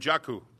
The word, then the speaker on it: Njoku: And Njoku, they tell me, is the pronunciation.